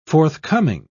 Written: forthcoming

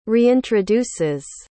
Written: reintroduces

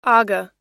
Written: aga

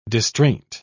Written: distraint

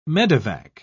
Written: medevac

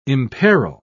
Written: imperil

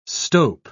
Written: stope

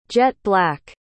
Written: jet-black

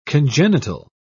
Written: congenital

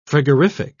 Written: frigorific

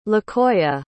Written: lequoia

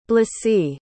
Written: blysse

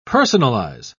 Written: personalize

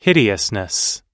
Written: hideousness

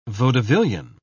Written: vaudevillian

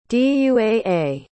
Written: duaa

